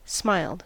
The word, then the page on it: smiled: American English